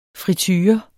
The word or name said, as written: friture